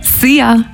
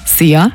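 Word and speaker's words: Szia: Sia. Hallo und tschüss. Könnt ihr euch ganz easy merken. – Sia. – Wird einfach ausgesprochen wie unser Energy Star. Sia.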